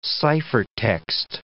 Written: ciphertext

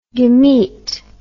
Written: gamet

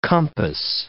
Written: compass

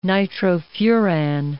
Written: nitrofuran

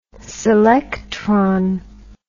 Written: selectron

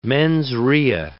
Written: mens rea

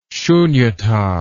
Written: sunyata

